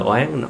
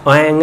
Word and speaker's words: oán: chứ không phải là oán. Oán mới đúng, oan á, oan là gần đúng rồi. Người mà người Bắc á thì đọc là oan, oan là đúng rồi, mà người Nam là quan luôn à, quan gia, thấy không? Như vậy giờ học thì biết chữ oán mới đúng.